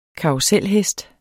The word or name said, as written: karruselhest